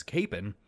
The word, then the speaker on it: Kapaun: I know some people out there will tell you that his name is pronounced Kapaun, and they very well might be right. But, after attending the high school named after him, going on a pilgrimage to his birth home, and generally just being exposed to the guy as Kapaun, I'm going to stick to my guns here. After all, we Kansans are simple people. We don't like complicating name pronunciations.